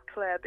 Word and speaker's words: Claer: I'm at Claire B. My name is spelled C-L-A-E-R, like aeroplane.